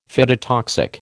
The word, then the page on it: phytotoxic: American English